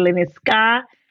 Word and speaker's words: Lenescat: My name is Naikey Lenescat. The T is silent.